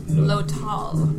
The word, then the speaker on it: Lothal: spoken like it's spelled, spelled like it's spoken. – What's his name again? – Jaldan Lothal. Lothal. Just think of it that way. – L-O or L-O-W? – L-O. Straight O. – Tall. T-A-L-L? – One L.